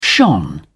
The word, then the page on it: shone: British English